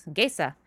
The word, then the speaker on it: Gesa: Gesa. Like quesadilla.